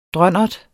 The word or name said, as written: drønnert